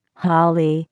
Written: holly

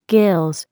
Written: gills

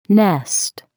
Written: nest

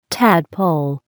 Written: tadpole

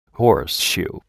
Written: horseshoe

